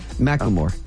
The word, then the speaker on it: Macklemore: I thank you for explaining that to me because I was kind of Macklemore. Did I say his name wrong? – You got it. Yeah. – I say Macklemore. – Yeah, Macklemore.